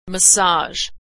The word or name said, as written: massage